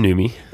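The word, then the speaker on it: Numi: It's new me. – New me. Organic tea. – Is that Newman's girlfriend? – That's how you say it. New me.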